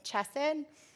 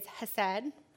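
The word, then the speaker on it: hesed (חסד): I embarrassingly pronounced the Hebrew word for that whole sermon, chesed. It is hesed.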